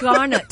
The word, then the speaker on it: garnet: Demantoid garment. – Right. – Demantoid, not a garment. A garnet.